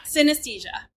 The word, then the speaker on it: synesthesia: There's a word for what Teddy can do, and I butcher it every time that I try to say it. It's like synesthesia. Oh. – Synesthesia. Yeah, synesthesia.